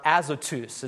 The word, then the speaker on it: Azotus: But Philip, it says in verse 40, found himself at Azotus, or Azotus is actually how you pronounce it.